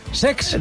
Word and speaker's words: Cesc: No lo he dicho mal, es Sex.